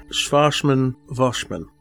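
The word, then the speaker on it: Schwassmann-Wachmann: Now, I think it is Schwarzmann-Voschmann. – That's well said. Sounds right. – Because it's German sounding, so yep. – The W's are pronounced V.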